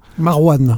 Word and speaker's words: Marouane: Euh, donc, pardon, il est signé de Marouin et Luasti, je le dis bien Marouane. Marouane, pardon.